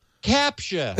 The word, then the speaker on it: CAPTCHA: CAPTCHA. – I was starting with the wrong syllable. Thank you. CAPTCHA.